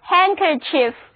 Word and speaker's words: handkerchief: 字母 D 还是不发音， handkerchief 就是手巾。